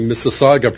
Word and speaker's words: Mississauga: By the way, full points for pronouncing Mississauga properly.